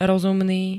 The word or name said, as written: rozumný